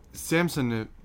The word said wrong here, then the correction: Samsonov: the goaltender for Washington, I can't pronounce his name. – Samsonov, that's what his name.